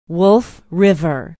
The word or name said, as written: wolf river